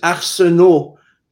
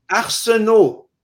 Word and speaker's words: Arsenault: It is Arsenault. You don't pronounce the LT.